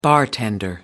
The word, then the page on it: bartender: American English